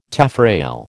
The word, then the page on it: taffrail: American English